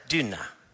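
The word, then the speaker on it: Tynne: Now, it's not spelled like tuna the fish, it's T Y N N E, and ultimately, with, with the best Finnish accent I have, it's Duna. Tuna. So it almost sounds like a D, but it's, I butchered that, sort of. But it sort of sounds like that.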